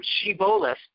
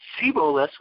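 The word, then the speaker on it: Shibboleth: Anytime someone from Ephraim would try to pass, the guards would ask them to pronounce the word Shiboleth with a shin. If they said the word Siboleth with a samich, this was a clear indication they were from the tribe of Ephraim.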